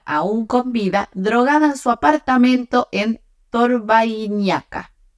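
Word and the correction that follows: Torvaianica: durante, no se dice así, Torvainica.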